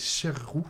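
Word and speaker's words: Sheru: Sarou, Saru, il le prononce mal, c'est Cherou.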